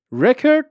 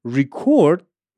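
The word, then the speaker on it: record: یکیه رکورد میشه همون رکورد خب اما ریکورد میشه فعل ضبط کردن I'm recording podcast این اصلا نقشش تو جمله میشه ورب میشه فعل ریکورد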